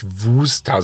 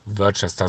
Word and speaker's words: Worcester: Also ich glaube, der Klassiker der falschen Aussprache ist die Worcester Soße, die ja eigentlich Worcester Soße ausgesprochen wird. Aber gesagt wird immer Worcester Soße, vereinzelt auch in Varianten.